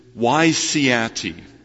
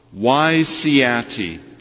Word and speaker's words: WYSIATI: Wysiati. Now it's spelled W-Y-S-I-A-T-I. It's not a real word. It's an acronym. And it stands for the phrase, what you see is all there is.